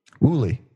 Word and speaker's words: Wuille: There's, how do you pronounce his name? Uwille, W-U-I-L-L-E. – Uwille. Yeah, Uwille.